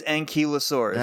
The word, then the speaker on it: ankylosaurus: It is ankylosaurus. – Ah, dang! We've been saying it wrong our whole lives.